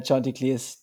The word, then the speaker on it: Chanticleers: Es geht um die Chanticleers, die Coastal Carolina Chanticleers, die Mallet. – Ähm, Heute auch mal richtig ausgesprochen.